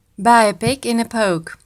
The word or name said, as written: buy pig in poke